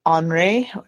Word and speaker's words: Henri: We have Iraq Linda, Iraq Henri, if you say it like a Frenchman, Iraq Modesta, Iraq Parnell, and Iraq Jalal.